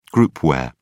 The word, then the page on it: groupware: British English